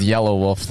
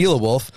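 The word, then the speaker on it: Yelawolf: We have a cousin that pronounces Yellow Wolf the rapper. Very uniquely. – Unique says, New York. – He says it like it's spelled, to be honest. – Yila Wolf.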